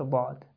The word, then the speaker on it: ض: That simple pronunciation of Dad, which is not mentioned in the book, is the Shami pronunciation, or the way that people in Lebanon and Syria and Jordan and some other countries pronounce the Dad. And that is exactly like Dal, Da. So it's the same as Dal, and it's pronounced from the same point of articulation, with the difference that Dad is pronounced very heavily and it fills the space of the mouth. So basically it's referred to as Dal Mufakham. Dal Mufakham means very heavy Dal.